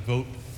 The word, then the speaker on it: Vote: and that's how we pronounce it, vote. It's simple. It's easy, V-O-T-E. It's how the current direct descendant of the builder pronounces the name vote.